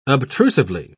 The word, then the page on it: obtrusively: British English